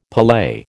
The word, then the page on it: palais: American English